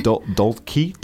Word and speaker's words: Dolce: Dolce.